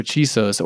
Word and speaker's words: Chisos: am i saying that right i am right Chisos. Chisos. – Chisos. The, uh, yeah, the Chisos Mountains out in West Texas. – That's ah, correct. that's right.